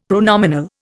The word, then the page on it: pronominal: Indian